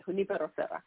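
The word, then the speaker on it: Junípero Serra: I'm like, okay, you break it down. It's like, Junipero Serra. That's how you say it, Junipero Serra.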